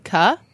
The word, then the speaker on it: kho: Well, no, I'm saying, so I've heard about this Vietnamese sauce called, it's spelled K-H-O. How do you pronounce that? Kuh? – That sounds good. Like pho? – It's kind of like pho.